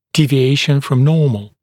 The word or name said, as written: deviation from normal